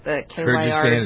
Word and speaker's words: Kyrgyzstan: Kyrgyzstan? – Kyrgyzstan is how you pronounce it? Yeah, or whatever. – Yeah, close. Good, thank you.